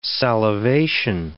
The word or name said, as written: salivation